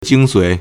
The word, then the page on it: 精髓: jīngsuǐ